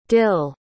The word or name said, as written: dil